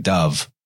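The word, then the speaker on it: dubh: It's pronounced like dove as well. That's a Gaelic word or Celtic word, dub.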